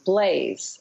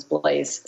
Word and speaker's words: Blaze: and so there's also a character named Blaze, and that's how the family pronounces that, as opposed to Blaz. And I think probably a little American twist on it, because Blaze sounds a lot better than Blas.